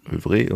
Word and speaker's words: Œuvre: Die Aussprache folgt dem französischen Œuvre und so weiter. Alternativ ist auch das etwas deutscher klingende Œuvre korrekt, beziehungsweise in unserer selbst eingedeutschten Lautschrift Œuvre mit Betonung auf der zweiten Silbe.